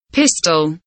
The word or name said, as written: pistol